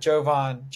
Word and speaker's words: Jovan: So this time around, I brought on now, I might butcher this, but it's Jovan, right? Jovan? – Yep, Jovan. – All right, Jovan.